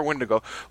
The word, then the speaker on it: Wendigo: So before we start our second episode discussion of, I believe, I can't pronounce this right, what is it? Wendigo? Wendigo? – Wendigo. – Wendigo. – That's how I see it, yeah.